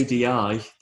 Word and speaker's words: Adi: Although it's ADI, the name, is pronounced a D.